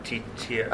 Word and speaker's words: Tetiaroa: Tetiaroa.